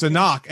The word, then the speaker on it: Anck: Like, I will say it in my defense with her name, I'm fine with saying Sunamun, but the problem is Anak is spelled A-N-C-K with no second A. So my brain always goes like Ankh. I was like, no, it's Anak.